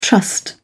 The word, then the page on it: trust: British English